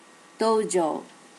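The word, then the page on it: dojo: native Japanese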